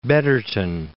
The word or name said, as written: Betterton